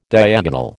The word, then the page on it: diagonal: American English